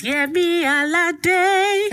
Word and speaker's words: Yemi Alade: I want you to say it how you say it in the songs. It's Yemi Alade.